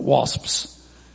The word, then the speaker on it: wasps: It sounds like two syllables because you can't say it in one syllable, wasps.